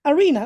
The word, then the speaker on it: Arena: I'm gonna spell it: ROM as in Mary, I, N as in Nancy, A as in Apple, Arena, like the concert arena, ARENA, which is my real name, believe it or not.